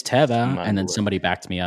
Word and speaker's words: Teva: Teva and Teva. Like it's actually Teva because that's what the company says is Teva.